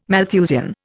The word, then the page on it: Malthusian: Indian